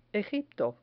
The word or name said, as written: egipto